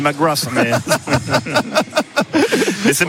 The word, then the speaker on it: McGrath: Alors, j'ai demandé si on disait McGrath ou McGrath, et c'est McGrath. – D'accord. – McGrath. Lui, il prononce rien à la fin.